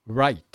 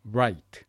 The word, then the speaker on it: right: L の方が Light、 R が Right。 2 つ並べて発音してみましょうね。 Light、 Right、 Light、 Right。